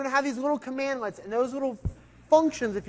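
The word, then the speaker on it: cmdlet: So CMD, LET, we can pronounce that commandlets.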